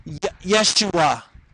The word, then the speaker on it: Yeshua: Joshua, as spoken in the original Hebrew language of the Old Testament, is pronounced Yeshua. Yeshua, that's what they would have called him.